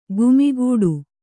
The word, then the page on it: ಗುಮ್ಮುಗಟ್ಟು: gummugaṭṭu